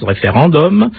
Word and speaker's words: référendum: Mais nous le prononcerons à la française, référendum, comme bibendum, et non endum, et ce n'est pas avec l'accent corse.